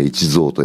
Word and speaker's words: 一三: であれでひふみと読むんだけど、この小林さんは 1 と 3 だけで、一蔵と読むんだね。